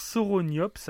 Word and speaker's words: Sauroniops: Alors, ça s'écrit comment par contre Alors, Sauroniops, alors ça s'écrit comme Sauron, S-A-U-R-O-N. Sauroniops.